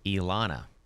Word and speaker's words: Elana: E-L-E-N-A. – Okay. My wife, her name is Elana. E-L-A-N-A.